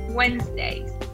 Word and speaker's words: Wednesdays: So I go swimming on Wednesdays. Wednesdays. Recordemos que son dos sílabas.